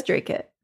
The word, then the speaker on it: Dracut: And I live in, you're going to have to tell me how to say this, Dracut, Massachusetts? Dracut? I think Dracut? – it is Dracut.